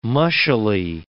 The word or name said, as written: mushily